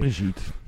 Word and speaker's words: Brigitte: Ik weet niet hoe ze het uitspreken. – Brigitte. – Brigitte vind ik ook prima. Zo zouden wij het doen in Nederland.